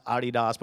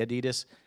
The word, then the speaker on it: Adidas: It's pronounced Adidas, but we say Adidas.